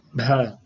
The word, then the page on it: bh: Sanskrit